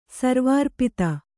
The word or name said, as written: ಸರ್ವಾರ್ಪಿತ